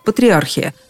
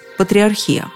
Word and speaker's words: патриархия: Я, например, до недавних пор говорила исключительно «патриархия», опираясь на указания самых разных словарей, от толкового словаря Ожегова до орфоэпического словаря под редакцией Аванесова. Кстати, последний, считаясь одним из самых авторитетных в области ударения – Вопреки обыкновению даже вариантов для этого слова не предлагал, только «патриархия» и все тут. Словарь ударений Резниченко тоже не преклонен «патриархия», но этот же словарь уточняет, у представителей духовенства распространено как раз «патриархия».